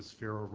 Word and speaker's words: Sphero: but then they have to use the Sphero robot as the ball. – How do you spell it? – S-P-H.